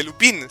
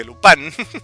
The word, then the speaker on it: Lupin: que no lo sepa, efectivamente no se dice Lupin, se dice Lupan.